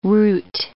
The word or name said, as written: root